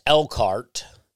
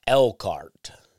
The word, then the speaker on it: Elkhart: I'm told it's Elkhart. You don't pronounce the H. It's spelled elk like the deer, and then H-A-R-T, Elkhart. But apparently it's pronounced Elkhart. Was corrected on my ad, Elkhart.